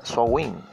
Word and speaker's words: Samhain: Pero Halloween entonces, y que tiene sus raíces en el antiguo festival celta conocido como Samhain, que se pronuncia Sowin, Sowin, Samhain, Sowin. No vaya a pensar Sowin acá desde la champeta que voy a meterme con el no, sino que se pronuncia así.